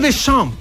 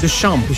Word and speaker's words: Deschamps: Didier Deschamps. – Ah. – Lo pronuncié bien, ¿o no? – 10 de 10. – Deschamps. Como René Descartes, es René Descartes.